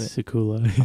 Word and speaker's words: Silkula: Silkula.